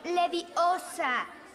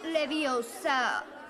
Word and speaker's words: Leviosa: Es leviosa, no leviosa. Hazlo tú si te crees tan lista. Hazlo, hazlo. – Wingardium Leviosa.